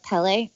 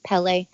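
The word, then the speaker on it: Pelle: So what do we think about the character of Pele? Or they call him Pel sometimes, but I think it's Pele. That's how they pronounce it.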